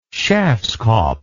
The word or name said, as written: schafskopf